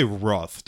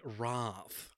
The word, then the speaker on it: wrath: Star Trek II: Wrath of Khan came out, and there's varying, scholars differ on how to pronounce that word. You say Wrath, don't you? – Oh, no. I say Wrath, like a Brit, like an upper class Brit.